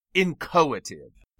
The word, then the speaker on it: inchoative: Note also that S has a technical name, incoative. I-N-C-H-O-A-T-I-V-E. The H is not pronounced.